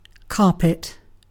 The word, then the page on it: carpet: British English